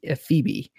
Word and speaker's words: Phoebe: It's with an H, but it's pronounced Phoebe.